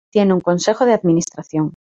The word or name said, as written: consejo